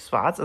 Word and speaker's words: Swartz: Äh, nein, der zweite Name ist nicht gelispelt, der heißt wirklich Swartz, also mit S und direkt danach ein W.